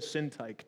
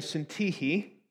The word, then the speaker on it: Syntyche: Because that looks like Yoda and Sintike to me. But uh, it is Euodia and Syntyche. That's Greek.